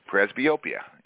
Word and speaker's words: presbyopia: Presbyopia.